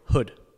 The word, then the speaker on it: Houde: Is it Houde? – Houde. – Okay, because I was pronouncing it wrong when I was doing the intro. – No, you're good. Literally everybody pronounces it wrong.